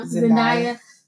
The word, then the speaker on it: Zendaya: Oh, Zendaya. Is that how you say her name? – Uh-huh. – Zendaya.